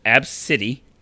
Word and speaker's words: Abcde: It is pronounced Ab-City. Ab-City. So A B C D E. Ab-City.